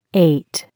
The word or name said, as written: Eight